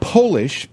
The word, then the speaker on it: Polish: Polish, P-O-L-I-S-H, is the only word in the English language that changes its meaning and pronunciation when it's capitalized. – Because it's Polish. – If it's not capitalized, it's Polish.